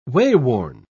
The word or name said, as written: wayworn